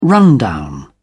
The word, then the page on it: rundown: British English